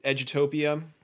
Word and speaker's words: Edutopia: What we're really doing with this is we had a, Edutopia. Did I say that right? – Yeah. – Okay.